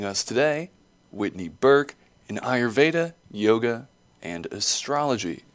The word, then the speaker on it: Ayurveda: So Ayurveda, you pronounce Ayurveda, like I as in the eyeball, Ayurveda.